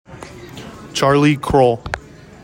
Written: CHARLIE CROWELL

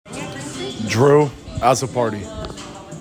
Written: DREW AZZOPARDI

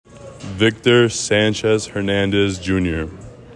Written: VICTOR SANCHEZ HERNANDEZ JR

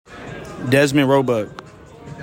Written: DEZMEN ROEBUCK

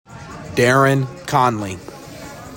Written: DARIN CONLEY

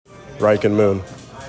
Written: RYKEN MOON

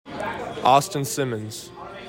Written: AUSTIN SIMMONS

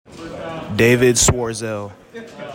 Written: DAVID SCHWERZEL

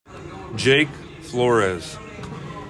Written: JAKE FLORES

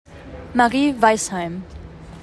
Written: MARIE WEISSHEIM

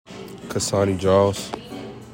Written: KSANI JILES

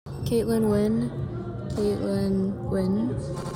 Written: Kaitlyn Nguyen

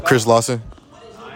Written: CHRIS LAWSON

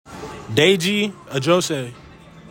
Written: DEJI AJOSE

